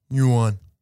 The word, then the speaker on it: yuan: The word yuan, as you say it, right? – Yes. – Is actually the way they pronounce it in China is yuan.